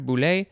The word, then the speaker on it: boule: This is a Greek word, boule, literally means will, W-I-L-L, from boulestai, which means to wish. And now we have the second form, but it is pronounced a little bit differently. It looks like it's just boule, one syllable instead of the two syllables for the last one.